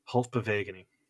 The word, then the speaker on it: halfbewegening: I think you're adding an extra syllable in there, Michael. – That's just the Dutch language. Halfbewegening, which is wave motion in English.